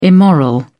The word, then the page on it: immoral: British English